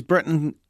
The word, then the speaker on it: Britain: Another one says Britain rhymes with kitten.